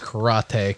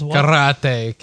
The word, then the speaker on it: Karate: The Elseworlds Karate Kid. – No, that's not how you say it. – Karate Kid. – Ooh, a little tongue roll there.